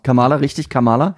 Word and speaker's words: Kamala: Kamala Harris.